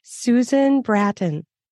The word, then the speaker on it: Susan Bratton: Her name is Susan Bratton.